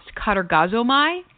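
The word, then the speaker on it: katergazomai (κατεργάζομαι): And in verse 12, when it says, "Work out your own salvation with fear and trembling," that word "work" is in the Greek a word that is pronounced katergazomai, and it means to perform or to achieve, okay?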